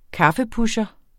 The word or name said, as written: kaffepusher